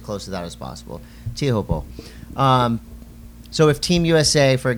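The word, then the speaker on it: Teahupo'o: and I've changed my pronunciation. – You know, I think everyone has. It's really weird. – I'm just going Tiahupo. I heard Mickey Manabre say it a bunch of times on the beach.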